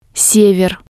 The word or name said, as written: sever